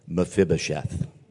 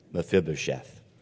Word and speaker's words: Mephibosheth: Mephibosheth. Mephibosheth.